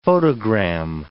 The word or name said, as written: photogram